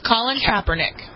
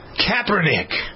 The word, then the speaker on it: Kaepernick: Is that how you pronounce his name? – Colin Kaepernick. – Kaepernick. – Right. – Boo! – He's a Colin, not a colon. – Boo! – Kind of acting like a colon more than boo!